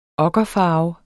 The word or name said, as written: okkerfarve